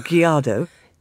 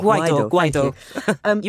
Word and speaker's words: Guaido: I think one of the most interesting questions that has come up out of this is, I'm sorry, I'm going to get a pronunciation of his name wrong, Guaido. Is that correct? – We call it Guaido. You were close.